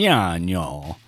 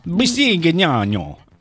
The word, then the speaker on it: Bisignano: I want to say her name right. – Oh, I could say it. It's Italian. – Bisignano. Bisignano. – Yeah, that's pretty good.